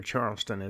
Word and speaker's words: Charleston: It's in Charlestown, South Carolina. Or Charleston, as it's pronounced usually.